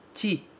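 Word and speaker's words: ti (チ): Ele é escrito C-H-I, mas a gente pronuncia ti, sempre ti. Ok? Esse CH no japonês sempre vai ter som de TI.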